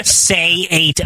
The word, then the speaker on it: Saito: Like S-A-Y, like say. – Yeah. – The number 8. Oh, yeah. Saito.